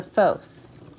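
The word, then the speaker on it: phos (φῶς): When I hear the word the Force from the movie, it brings to my mind a Greek word with similar pronunciation, the phos, which means the light.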